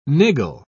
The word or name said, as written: niggle